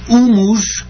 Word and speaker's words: humus: Humus, yo pronuncio a veces en con la H aspirada, porque es una palabra casi internacional, aunque procede del latín.